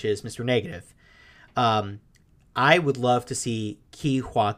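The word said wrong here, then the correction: Ke Huy: Kwan, if I'm pronouncing it right. – Ki-Hui Kwan.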